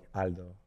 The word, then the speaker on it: Aldo: Because when they ask my name, I just go like Aldo, like straight, like I was pronouncing it in Spanish, you know?